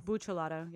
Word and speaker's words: Buccellato: Is it Buccellato? – Buccellato.